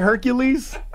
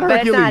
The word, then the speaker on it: Hercule: It's spelled like Hercules. – Call him Hercules. – No, it's not Hercules. – It's Hercule.